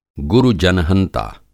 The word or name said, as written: गुरु-जनहंता